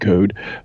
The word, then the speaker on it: Coad: We have an email from a Paul, I'm going to pronounce this, C-O-A-D, Coed, Coed, Code? – C-O-A-D, Like road, but code.